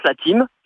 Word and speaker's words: Thym: Alors, ça s'écrit comme le thym, T-H-Y-M, mais ça se prononce La Team.